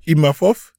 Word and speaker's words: Imavov: Eh, hoe spreek je zijn naam uit? Noesadin Imavov? Ja, die. Ja. Imanov. Imavov? Ima? Imavov, ja. Imavov, oké.